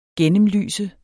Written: gennemlyse

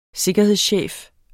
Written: sikkerhedschef

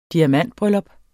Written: diamantbryllup

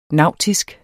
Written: NAUTISKE

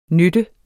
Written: nytte

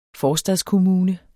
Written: forstadskommune

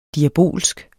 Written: DIABOLSKE

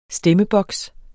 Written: STEMMEBOKS

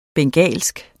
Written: bengalsk

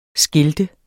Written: skilt

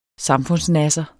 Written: samfundsnasser

